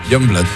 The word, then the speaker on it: Youngblood: Es que era muy difícil de pronunciar, pero luego, ¿cómo se pronuncia? – Youngblood.